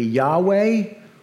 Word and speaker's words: Yahweh: We've added the vowels, and it could be pronounced alternately Yahweh, or perhaps you've heard of it pronounced as Jehovah.